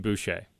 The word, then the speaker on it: Bouche: It's spelled B-O-U-C-H-E. How was it growing up in Louisiana with the name Bouche? – It was interesting.